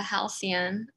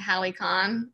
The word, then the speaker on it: Halcyon: So once you reach your destination, which is the Halcyon, I looked up the pronunciation because I was also calling it the Halicon,